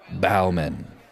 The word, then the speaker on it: Baumann: Bauman. – Could you spell that? – B-A-U-M-A-N-N.